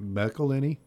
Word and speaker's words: McElhenney: You slur it. You just don't pronounce any of it completely. – Oh, okay. – And just let it, McElhenney? – Just kind of smush it together and yeah. – call it done.